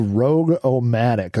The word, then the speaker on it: ROG-O-Matic: Now, one thing that I found really interesting around this same time was a program that was released called Rogue-O-Matic, and that is spelled ROG-O-Matic, but it's pronounced Rogue.